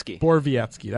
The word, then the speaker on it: Borowiecki: Borowiecki.